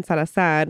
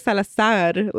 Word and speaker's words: Salazar: I sometimes accidentally don't say Salazar. Like I don't put the emphasis the last A.